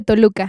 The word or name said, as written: Nevado de Toluca